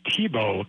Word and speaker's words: Thibault: You were very close. It's Mark Thibault, kind of like the football player Tim Thibault, though we spell it differently, pronounce it the same.